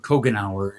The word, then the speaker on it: Kojinar: I wasn't very good with the phonetic effort, but Kojinar is the proper pronunciation.